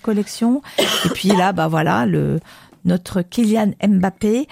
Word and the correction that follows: Mbappé: Non, c'est pas comme ça, c'est Mbappé, la prononciation, et lui, c'est la pépite.